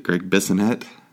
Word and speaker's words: Bissonette: In the band at the time had Steve Vai, Billy Sheehan, and is it Greg Bissonette was the drummer? – Yeah, that's right.